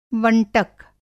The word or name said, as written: वंटक